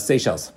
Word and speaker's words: Seychelles: Well, and then, how does this pronounce again? Chichilis? – No, it's Seychelles.